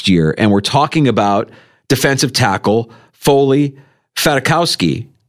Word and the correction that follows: Fatukasi: I'm sorry, I said his name wrong. Fatukasi is with the Bills today.